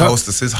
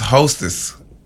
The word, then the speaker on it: Hostess: And it was by Hostess. – Wow, okay. That's a first for me. – Not Hostess, Hostess. Hostesses. – Same thing. – Yeah. You said it wrong.